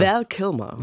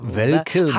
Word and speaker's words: Val Kilmer: Der verstorbene US-Schauspieler, wie spricht man den eigentlich? – Val Kilmer. – Val Kilmer.